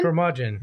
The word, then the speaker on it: curmudgeon: Is a, how do I say this again? Curmudgeon? – Curmudgeon.